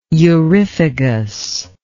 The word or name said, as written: euryphage